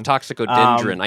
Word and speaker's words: Toxicodendron: Toxicorgin, I'm definitely saying the Latin name wrong. – Toxicodendron. I know that because I've talked a lot about poison ivy.